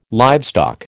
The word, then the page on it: livestock: American English